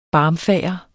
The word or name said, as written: BARMFAGERT